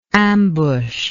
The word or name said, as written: ambusher